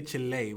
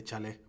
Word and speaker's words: chile: When we say Chile, we're not trying to say Chale, by the way.